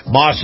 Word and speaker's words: Maus: And by the way, this portion of the program brought to you by my good friends at Moss Nissan. Moss is spelled M-A-U-S Nissan.